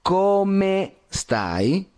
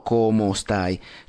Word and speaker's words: come stai: En este caso, justamente se dice ciao, come, come stai, no como stai.